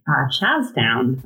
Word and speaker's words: Charlestown: And Charlestown, Betty, or as locals say, Chazstown.